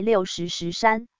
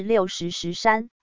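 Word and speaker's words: 六十石山: TVBS 播报 60石山时，发音竟遭观众骂翻，因此 TVBS 新闻部就跟花莲县富里乡公所的农业课查证 60石山的读音，结果正解的读音是 60石山，而不是 60石山。这个认知的不同是由于石字在石头沙石时读音是湿第二声水，观众对于 60石山这地名也表疑惑，难不成此山仅有 60块大石？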